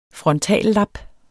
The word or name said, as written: frontallap